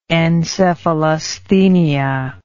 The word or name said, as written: encephalasthenia